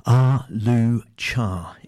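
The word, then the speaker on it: Ah-Leu-Cha: Ah-Loo-Cha is the correct pronunciation of that.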